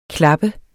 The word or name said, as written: klappe